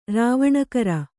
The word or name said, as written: ರಾವಣಕರ